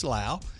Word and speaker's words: Lowe: Our diversity officer, Michelle Lau, it's spelled L-O-W-E, but her name is Lau.